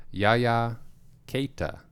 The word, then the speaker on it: Keita: Yaya Keita is how they pronounced it on the broadcast.